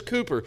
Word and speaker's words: Cowper: His name is spelled Cowper, so if you're from the the U.S., you probably see it and say Cowper, but his name is Cowper.